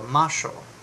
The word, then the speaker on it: Moxo: I'm saying it in Portuguese. The X makes a sh sound. So, Macho.